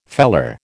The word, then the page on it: feller: American English